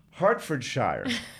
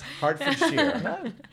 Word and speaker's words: Hertfordshire: Hertfordshire. And I said that wrong. It's Hertfordshire.